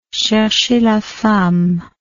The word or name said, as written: cherchez la femme